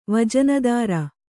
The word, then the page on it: ವಜನುದಾರ: kannada